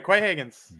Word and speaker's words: Quay Higgins: Quay Higgins, I guess it's not that hard, unless I said it wrong. Quay Higgins. – No, I think you said it right. Quay Higgins.